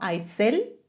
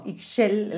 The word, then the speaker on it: Ixchel: Que a lo mejor nosotros ya lo hemos evolucionado un poco como Aitzel, pero es como Ixchel, la pronunciación maya, ¿no?